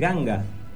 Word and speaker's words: Ganga: Si a un norteamericano, una persona que de habla inglesa, le decimos Ganga, la asumiría a escribirla como se escribe Gan, arma. Que se escribe G-U-N, no G-A-N.